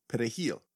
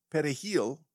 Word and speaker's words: perejil: If you said perejil without trilling the R, it meant that you were likely Haitian.